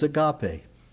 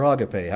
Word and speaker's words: agape: And as you probably know, the word translated love here is agape, or agape, however you want to pronounce it.